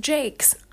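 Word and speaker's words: Jacques: It is pronounced Jakes.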